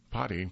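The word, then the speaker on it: Potti: Potty, pronounced Potty,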